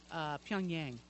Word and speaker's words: Pyongyang: Pyongyang.